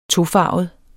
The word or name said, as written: tofarvet